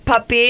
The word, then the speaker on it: Pepe: Peppy. – Close enough. – Spelled like Pepe, but Puppy is what they call him.